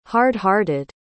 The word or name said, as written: hard-hearted